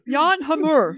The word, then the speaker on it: Jan Hammer: There's also some like wannabe Jan Hammer. I mean, sorry. Someone on Facebook told us that we're pronouncing his name wrong. His name's like Jean Hamour or some shit.